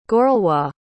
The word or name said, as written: gorlois